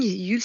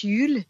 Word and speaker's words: Jul: C'est un mot viking, Yule, ça s'écrit J-U-L, ça se prononce Yule.